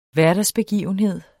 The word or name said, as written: hverdagsbegivenhed